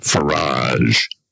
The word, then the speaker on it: Farage: Nigel, in case you're wondering, refers to the leader of the Reform Party, a controversial, to put it mildly, politician called Nigel Farage. Rhymes with garage or fromage.